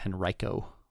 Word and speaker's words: Henrico: I think Henrico is Henrico, but either way, pretty good.